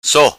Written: So

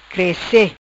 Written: Crece

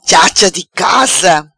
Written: Chacha Di Casa

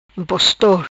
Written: Impostor

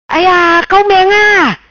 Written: Cao Meang Aia

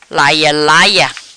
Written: Laia Laia

